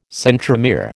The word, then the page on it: centromere: American English